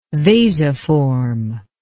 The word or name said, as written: vasiform